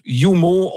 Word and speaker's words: UMO: Ça s'écrit UMO, ça se prononce Yumo.